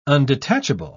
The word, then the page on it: undetachable: British English